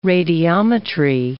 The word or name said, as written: radiometry